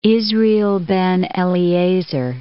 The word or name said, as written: Israel ben Eliezer